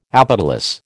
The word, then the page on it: apetalous: American English